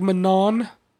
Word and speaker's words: Manon: The way they pronounce it is Manon. – And the N is in the nose, yeah. Like French. Manon.